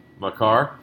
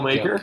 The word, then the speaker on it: Makar: Now, do you guys remember when Dave drafted him and he said his name wrong? – What did he call him? – Cale Maker? – Cale Maker.